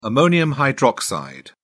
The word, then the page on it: ammonium hydroxide: British English